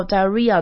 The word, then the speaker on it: diarrhea: germs that cause diarrhea。 diarrhea 这个字可能比较多人不会念，它就是腹泻的意思，腹泻拉肚子。然后 diarrhea 的话，重音在后面的 R。 R 的地方就是比较像是一个 RIA 的发音，RIA 的感觉，所以就把它想成 diarrhea，像 diary 是日记，然后这边变成 diarrhea，就是变成腹泻。